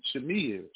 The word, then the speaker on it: chimera: And Ramaswamy highlights some of the many complexities surrounding human chimeras. And chimera is spelled C-H-I-M-E-R-A-S.